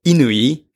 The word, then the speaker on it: Inui: Like, for example, there are a player called Inui. – Okay. – It's like I-N-U-I.